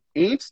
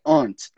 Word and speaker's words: aunt: Ant, na pronúncia do sotaque americano, né? Que é a mesma pronúncia do animal. Ant. No sotaque britânico é aunt. É um pouco mais fechado. Mas a gente, de forma alguma, pronuncia aquele U. Aunt. Nunca, nunca.